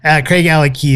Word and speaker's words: Craigellachie: Craigellachie is the way you really pronounce it.